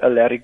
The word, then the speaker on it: Alaric: I'm on Instagram and Facebook at Alaric Basson. That's it. – Alaric, sorry, I've been saying your name wrong all the time. Alaric Basson, yes. – Double S-O-N.